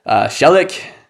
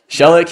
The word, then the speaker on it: Szalek: I went to the call room, and at the call room, they call out my name, and they're looking for Szalek. And that's not how you say my name in English. It's Szalek. Szalek. And I was looking around, I was like, where the fuck is this dickhead?